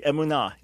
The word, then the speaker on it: emunah: No, but you pronounced it correctly, emunah.